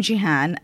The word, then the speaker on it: Gihan: Yes, I am Gihan, and my consultancy is called Communique.